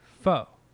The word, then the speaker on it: faux: Faux.